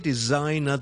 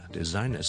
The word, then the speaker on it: designer: Number two, when you say designer, the S in the middle is actually pronounced as a Z. It's design, 是一个 Z 音来的中间的 S. Z, so it's designer, so graphic designer.